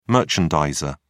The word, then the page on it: merchandiser: British English